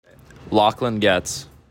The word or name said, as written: Getz